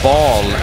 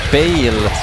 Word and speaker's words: Baal: Jag försöker få min fix ifrån eh, till exempel Temple of Baal, eller Bale som det faktiskt uttalas.